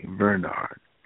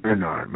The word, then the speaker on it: Vernard: The first name is spelled with the V as in victory. V-E-R-N-A-R-D, Bernard. Yeah, I know somebody said, well, I thought it was Bernard. No, it's Bernard. My mother specifically named me Bernard. Amen. Bernard Johnson. Johnson, New York, H-N-S-O-N.